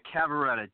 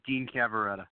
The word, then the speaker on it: Cavaretta: You were very close, Rory. Cavarata, Dean Cavarata.